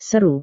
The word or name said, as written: seru